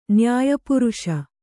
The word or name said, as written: ನ್ಯಾಯಪುರುಷ